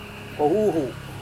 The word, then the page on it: kohuhu: Serewen